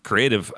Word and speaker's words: CRTV: creative, I guess would be a way to say it. And for folks looking for it, it's spelled CRTV Lab, all caps. It's pronounced creative.